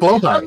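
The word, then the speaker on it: Blåhaj: And that's actually pronounced Blahaj.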